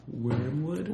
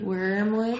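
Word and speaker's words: Wyrmwood: And it's spelled W-Y-R-M, Wood. Wormwood. – Wormwood.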